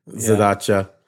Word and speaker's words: Zadacha: Zadacha.